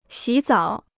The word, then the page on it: 洗澡: xǐ zǎo